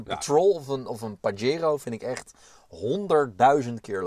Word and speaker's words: Pajero: Pajero, daar uitgesproken als Pajero. Pajero.